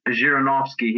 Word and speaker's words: Zhirinovsky: Now, I want to make sure I'm pronouncing his name right. Zhirinovsky? – Right, yeah, Vladimir Zhirinovsky.